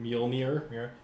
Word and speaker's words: Mjolnir: Mjolnir. – Mjolnir.